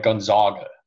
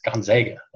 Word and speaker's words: Gonzaga: but they pronounce the school wrong. They're like, Gonzaga. And it's like, no, it's Gonzaga.